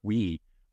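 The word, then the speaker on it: Guiu: Mark, I think it's pronounced Mark Wee, also seventeen year old that that came in.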